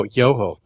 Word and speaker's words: Yoho: I should have looked up a picture of this Yahoo. – No, Yoho, not Yahoo.